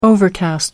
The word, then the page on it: overcast: American English